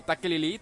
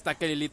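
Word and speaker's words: Tekeli-li: Hay unos nativos ahí que hacen un ruido que es como taquelili, taquelili, algo así, bueno, así se escribe. Y al parecer el Cho'Goth, H.P. Lovecraft le dio el mismo sonido al grito que da el Cho'Goth, que es taquelili, taquelili.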